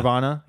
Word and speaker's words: Carvana: Next up is Carvana. – Yeah. – Is that how you say it? Carvana? – Yeah, I think so. I don't know. You're asking It's like Piranha. – Yeah. – Carvana.